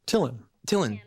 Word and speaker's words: Thielen: Jody Thielen, right? Is that how you pronounce her name? – Jody. Hell yeah. – Thielen, I think, or something like that. – Tillen. Yeah.